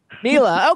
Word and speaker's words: Mela: Mela. Okay, everybody, welcome Mela Hudson to the Jimmy Star Show.